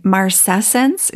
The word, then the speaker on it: marcescence: And you're going to have to pronounce this word for me. Marcescence, is that what it is? – Yeah, you got it.